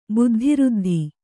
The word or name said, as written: ಬುದ್ಧಿಋದ್ಧಿ